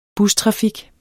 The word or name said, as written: bustrafik